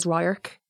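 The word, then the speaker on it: radharc: I think my number one is Ryark, which means view. I've always loved it. I love saying it. I love how it's spelt. I love how it's not spelled the way it's said.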